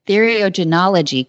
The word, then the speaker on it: theriogenology: We did a nice introduction of you, and I hopefully pronounced theriogenology correct. Is that right? – You did. You did.